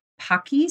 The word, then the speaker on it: Pocky: One year for something else entirely, we did a cake with Pocky sticks.